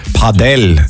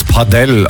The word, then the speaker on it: Padel: Und habe mit meiner Freundesgruppe am Wochenende das erste Mal Paddle gespielt. – Ah, kleine Korrigierung gleich. Ich habe es auch noch nie gespielt. Ja. Ich meine, Padel. Es ist wohl Spanisch. Man soll es Padel aussprechen.